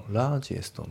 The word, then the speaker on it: Largest: あと発音ですけど、 Largest の T がね、抜けますね。 Largest Number。 Largest Number じゃなくて、 Largest Number。あとその左下のフィールドアットのところがリンキングが起きてて、さらに T が抜けますね。